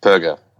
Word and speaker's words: Perger: Perger. Hard G. Burger with a P.